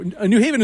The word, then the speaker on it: New Haven: No, New Haven is the correct way to say it.